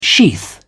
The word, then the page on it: sheath: British English